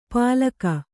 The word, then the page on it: ಪಾಲಕ: pālaka